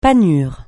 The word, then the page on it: panure: francais